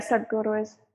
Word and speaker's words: Sadhguru: The first one that came to mind is Sadhguru. – Why? – I'm pronouncing it correctly. – Yeah, Sadhguru is good.